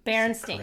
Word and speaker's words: Berenstain: Berenstain. – Berenstain. – Correct pronunciation of that.